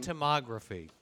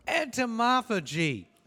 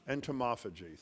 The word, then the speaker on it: entomophagy: Entomography. – No, there's no R. – Wait. – There's no R. – Entomophagy. – Entomophagy. Thank you right.